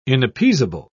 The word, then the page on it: inappeasable: British English